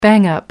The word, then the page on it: bang up: American English